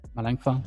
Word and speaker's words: Melanchthon: So, you know, he quoted Philip, I can never pronounce his last name, Philip Melanchthon. – Melanchthon, yeah.